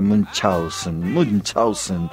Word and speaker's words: Münchhausen: Barón de Münchhausen, o de Münchhausen, como quieras pronunciarlo. En realidad se pronuncia Münchhausen, que algún alemán me lo desmienta.